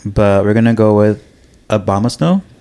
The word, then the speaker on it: Abomasnow: Okay, how do you say it? – I think you said it right. – Okay, cool. – So Abomasnow. It was so close. It was right.